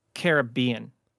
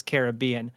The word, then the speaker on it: Caribbean: Oh, Dave, it's Caribbean. – Oh, that's right. good callback. – So yes, previous show we actually looked up the correct pronunciation and it is Caribbean.